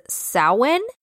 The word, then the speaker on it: Samhain: The way the name sounds is Samhain. But it's spelled like Sam Hain, so it's S-A-M-H-A-I-N, so that's where he got Son of Sam.